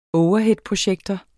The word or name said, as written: overheadprojektor